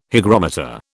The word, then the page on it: hygrometer: British English